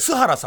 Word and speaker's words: 数原: うん、真面目な方です。で、あと、三菱鉛筆の社長の、あの、か、数字の数、か、数原さんとか、須原さんって読むんですけど、須原さん。